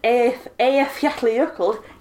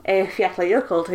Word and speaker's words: Eyjafjallajökull: Or the Eyjafjallajökull, yes, I do know how to pronounce it, volcano from 2010. Eyjafjallajökull again.